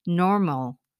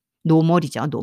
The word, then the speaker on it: normal: 여기 R 사운드 있어서 좀 힘들 수 있어요. nor, normal sound, normal. 아, 그러니까 이런 게 빨리 발음하면 빨리 발음이 아니지. 외국 친구들이 발음하면 앙 이렇게 들 뭐, 뭐래 지금 순간 무슨 발음 지나가서 이렇게 들려요. 이노 그 한국말로 노말 있잖아요. 노말이 왜 그러냐면 r 사운드하고 끝에 l 사운드가 있기 때문이에요.